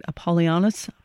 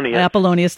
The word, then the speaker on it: Apollonius: Apollonius. – Apollonius, thank you, who actually lived after Jesus, yet some saw these two divine men as being in competition.